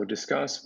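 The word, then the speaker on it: Disqus: So Discuss, which is pronounced as in discussion, but spelled in sort of a weird old school web 2.0 way with a Q.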